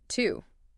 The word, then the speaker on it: TU: Which brings us to Planet Two, TU, pronounced two.